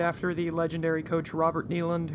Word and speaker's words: Neyland: Neyland. Yes, you are. – I could have sworn it. – I think it's Neyland.